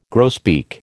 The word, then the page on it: grosbeak: American English